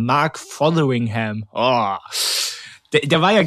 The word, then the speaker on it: Fotheringham: Und dann, weil du dieses, weil du dieses TH wieder hast, ne, musste immer Fotheringham. Mhm. Ich habe das wirklich die ganze Woche geübt, damit ich es hier heute ordentlich ausspreche. Mark Fotheringham.